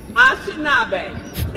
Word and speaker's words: Anishinaabe: Anishinaabe? Anishinaabe.